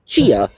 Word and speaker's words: Tchia: And it's spelled T-C-H-I-A, but pronounced Chia. The T is silent, like nuclear.